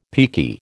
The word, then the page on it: peaky: American English